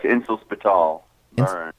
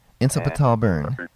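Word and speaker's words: Inselspital Bern: How do you say that? – Oh, that's Insel Spital Bern. – Insel Spital Bern.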